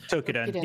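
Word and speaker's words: Toukiden: Tokiden, whatever that game is. – Tokiden, you had it right.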